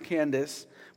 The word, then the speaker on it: Kandake: Kandake, and in another version, it's pronounced Candace, which means the queen of Ethiopians.